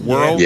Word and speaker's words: WRLD: I guess that's how you pronounce that. WRLD World.